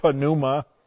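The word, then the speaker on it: pneuma (πνεῦμα): We in English do not say, we say pneumatic, we don't say the P, but in Greek, every letter is pronounced, so it would properly be called pneuma in the New Testament.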